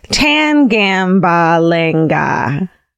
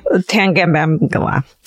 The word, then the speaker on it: Tangambalanga: This story is from my parents' hometown of Tangambalanga. And then it says, you're welcome, because they wrote it out. – They phonetically. – Tangambalanga. You're welcome.